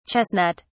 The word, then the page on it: chestnut (s): British English